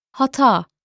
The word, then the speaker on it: hata: An example is hata, which means error or mistake. The Turkish h is never silent, as sometimes happens with h in English.